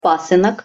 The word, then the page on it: pasynok: Russian